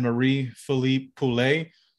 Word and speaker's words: Marie-Philippe Poulin: The Canadian signed Marie-Philippe Poulin. I know I said it right because I believe I've practiced it about six or seven times and I can't fuck it up like I did Sarcastic Remarks.